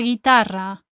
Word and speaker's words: guitarra: La guitarra. The guitar. La guitarra. Notice how the letter U is silent in those eight words.